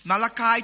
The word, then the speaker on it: Malachi: Malachi chapter 2. When I was growing up, I thought it's Malachi. I pronounce it Malachi. But it's Malachi.